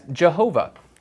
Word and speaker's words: Jehovah: Sometimes you'll hear that said as Jehovah, because they put those two vowels in. Those vowels aren't there.